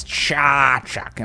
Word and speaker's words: Čačak: And I end up getting a bunch from like Čačak. – Yeah, you know? Well, you said that right. – Well, you know what? Because I said it wrong 1,000 times, and then someone said, no, it's Čačak.